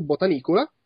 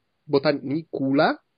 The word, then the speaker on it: Botanicula: Botanicola. Botanicula.